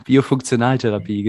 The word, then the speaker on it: Biofunktionaltherapie: Das ist jetzt richtig ausgesprochen. – Ja, ja, Biofunktionaltherapie, genau. – Okay, lassen wir es beim Deutschen, ja.